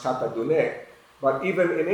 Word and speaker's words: Tatadune: it's spelled D-U-N-E, and I said it's Tatadun, and that's wrong. It's Tatadune.